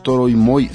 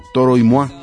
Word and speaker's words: Toro y Moi: Yo siempre pensé que Toro y Moy era un dueto, y resulta que no es dueto, es un solo músico, y además no es Toro y Moy, sino que hay que pronunciar la tercera palabra en francés, es Toro y Moi.